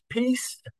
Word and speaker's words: PiS: The initials are PIS, which in Polish is pronounced peace, the same way as we might say world peace.